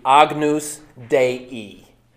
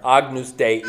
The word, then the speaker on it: Agnus Dei: But if you're pronouncing it with the Latin, it would be Agnus Dei. So Latin does that funny thing there, Agnus Dei. Or if you say Agnus Dei, nobody's going to look at you funny.